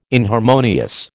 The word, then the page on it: inharmonious: American English